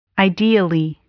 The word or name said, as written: ideally